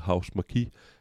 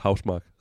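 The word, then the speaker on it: Housemarque: Øh, og det, det var dem, øh, hvad hedder det, vi, jeg tror, fordi de staves, øh, det hvor man tænker, man naturligt udtalte det, House Marquis, men øh, det udtales faktisk Housemark ret øh, flat, øh, det her finske spilstudie.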